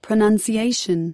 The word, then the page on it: pronunciation: American English